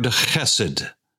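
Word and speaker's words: chesed (חסד): It is the word chesed, C-H-E-S-E-D, not chesed, but chesed. It's a guttural sound, chesed, and it is spelled C-H-E,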